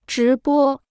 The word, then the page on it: 直播: zhí bō